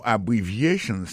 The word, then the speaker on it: abbreviations: Abbreviations, accent tonique la, tombé sous troisième syllabe la. Dans mot ça, il y a quatre syllabes. C'est A, une syllabe. Bri, c'est deuxième syllabe là. Vie, c'est troisième syllabe là. Chan, c'est quatrième syllabe là.